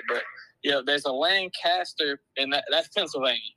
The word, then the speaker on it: Lancaster: Lancaster. I tell everybody it's, you just pronounce it one syllable. You just, all one word, just run it together.